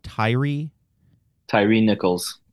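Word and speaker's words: Tyre Nichols: Tyree Nichols.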